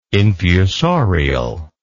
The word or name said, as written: infusorial